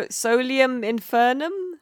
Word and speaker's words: Solium Infernum: That's a Solium in, but I can never say it right, like Devil Civ. – Yeah, so Solium Infernum? – That's right, yes.